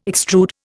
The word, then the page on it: extrude: Indian